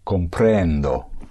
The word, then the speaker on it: comprendo: I understand would be comprendo. – Comprendo. Push down, comprend, and you surface on O. Comprendo.